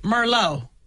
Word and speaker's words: Merlot: Yeah, remember Merlot is, and that T is silent the T on the end of Merlot.